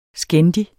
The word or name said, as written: skændig